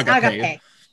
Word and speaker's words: Agape: After Neon was, I believe it's pronounced Agape, not Agape, because it's like the Greek concept of Agape. It's Agape. That's the Greek for you.